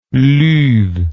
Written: Ludd

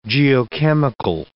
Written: geochemical

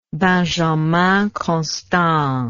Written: Benjamin Constant

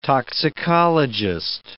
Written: toxicologist

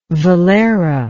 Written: Valera

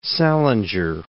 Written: Salinger

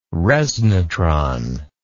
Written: resnatron

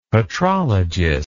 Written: patrologist

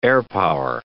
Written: airpower

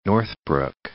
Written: Northbrook

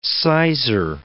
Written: sizar

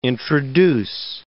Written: introduce

